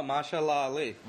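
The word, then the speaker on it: Mahershala Ali: Oh, Mashallah Ali. – Mashallah Ali.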